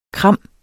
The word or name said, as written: kram